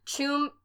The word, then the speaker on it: Tuam: Tuam, which is spelled T-U-A-M, because Ireland. – T-U-A-M.